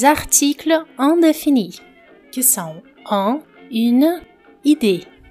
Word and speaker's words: une: Une fille para falar in, esse som inicial é i. É como se você estivesse falando um i, mas com os lábios arredondados como se fosse um u. Ou seja, você pronuncia i, mas os lábios são arredondados.